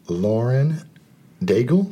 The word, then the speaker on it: Lauren Daigle: Lauren Daigle. – Mm-hmm. – Is that how you pronounce that? – Mm-hmm.